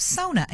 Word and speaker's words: sauna: If you're just joining us this morning in our wellness segment, we're talking about the benefits of sauna. And yes, that is how you pronounce it.